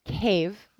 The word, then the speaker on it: Kyiv: What they do is they run things in Kyiv, which is actually the correct way to say Kyiv,